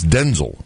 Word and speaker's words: Denzel: It's Denzel, not right. Denzel. – Right. Really? – Like in Hansel and Gretel. So maybe they'll make the new movie Denzel and Gretel.